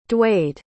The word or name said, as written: Dwade